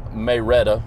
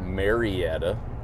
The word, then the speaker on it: Marietta: And we actually have about a 2-hour trip over to the other side of Mayreta. That's how you say it in Georgia. If you are not from Georgia and you look at it, it's pronounced Marietta. But we're going to Mayreta.